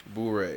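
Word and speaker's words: Bourré: It's called Boo Ray. There we go. – Boo Ray. – Boo Ray.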